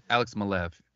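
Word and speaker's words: Alex Maleev: I think I'm saying his name right. – Alex Malev. Yeah. – Alex Malev. There we go.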